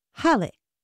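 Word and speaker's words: Hallé: But it is pronounced with the stress on the first syllable, Halle.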